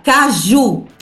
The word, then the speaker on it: caju: Otra, caju. – Caju. – La castañas. – Aí esse J, não? La J, pronunciamos como ju, como se fosse a Y del mar platense.